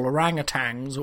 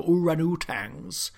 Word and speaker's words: orangutans: We're gregarious gibbons, and frankly awful orangutans or oranutans, to use the correct pronunciation. And I will only use the correct pronunciation, oranutans.